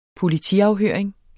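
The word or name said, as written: POLITIAFHØRING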